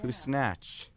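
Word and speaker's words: Küsnacht: Because that's how you say it. – Well, that's how it's spelled. – K-U-S-N-A-C-H-T. Yeah. Kusnatch.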